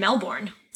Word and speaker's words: Melbourne: So Melbourne, for people who are listening from outside of Australia and don't know what we're talking about, Melbourne is spelled M-E-L-B-O-U-R-N-E, and they say it as? – Melbourne.